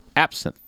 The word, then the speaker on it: Absinthe: That is my review on Absinthe. – Fun. – Did I say that right? – You did.